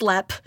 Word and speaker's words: Leap: it's pronounced lep.